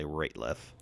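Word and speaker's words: Rateliff: Ratliff. Yeah, I think it's falsely pronounced Ratliff a lot, but it's definitely Ratliff.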